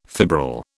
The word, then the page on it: fibril: British English